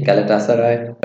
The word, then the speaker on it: Galatasaray: Galatasaray, Fenerbahce.